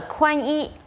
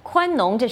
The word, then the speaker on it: 宽农: 我们听到了宽衣、宽农，这实际上应该是农字，但是习近平把它念成了一字。